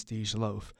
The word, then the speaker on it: Dej Loaf: No, I didn't make that name up, nor am I pronouncing it wrong. The artist is Dej Loaf.